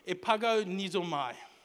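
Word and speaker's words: Epago, nizo, mai (ἐπαγωνίζομαι): That word contend is actually a Greek word which is pronounced epago, nizo, whatever. Epago, nizo, mai.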